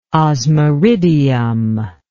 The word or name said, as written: osmiridium